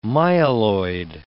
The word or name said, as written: myeloid